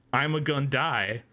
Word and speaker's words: Ima Gun Dai: Wikipedia says his name, pronounced like Ima Gun Dai, foreshadows his death in the episode.